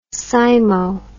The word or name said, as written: cymo